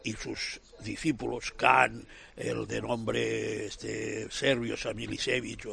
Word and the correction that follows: Salihamidžić: o así. – Sí, Salihamidžić. – Salihamidžić, todo eso, porque yo a estas pronunciaciones no llego.